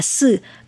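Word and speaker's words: sự (四): vì trong tiếng Trung số 4 đọc là sự, có âm đọc rất giống với chữ tử trong tiếng Trung đọc là xử có nghĩa là chết.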